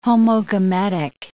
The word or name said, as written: homogametic